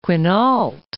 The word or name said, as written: Quinault